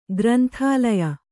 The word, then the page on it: ಗ್ರಂಥಾಲಯ: granthālaya